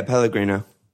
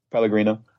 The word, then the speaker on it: Pellegrino: And then once Puel left, you got Pellegrino. Am I saying that right? I always get him confused with the West Ham manager. – Yeah, Pellegrino. – Pellegrino, who played bad football and got bad results.